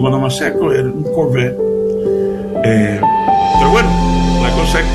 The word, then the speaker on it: Corvette: Nosotros, en inglés se dice Corvette, pero en español nosotros decimos Corvette, porque así es como se escribe, ¿no? C-O-R-V-E-T-T-E, Corvette.